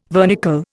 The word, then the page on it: vernicle: Indian